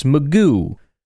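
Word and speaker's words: McGough: Packers intend to sign USFL MVP quarterback Alex Magoo. I really did not pronounce it that way until I went and watched his highlight video thing. And that's how they pronounced it, and I was sad.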